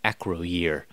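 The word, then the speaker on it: Acroyear: And I had to figure out how to pronounce a Groyer, because it looks like Acroyer. So I went and went to YouTube to look up some commercials of the old toys to see how Mego themselves, the toy company Mego, pronounced this character's name. And they do indeed pronounce it Acroyer.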